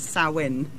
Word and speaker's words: Samhain: So is this tied up with, if I'm pronouncing this right, Samhain? – Samhain. I'm going to pass that one over. – Yes, it is. It is Samhain.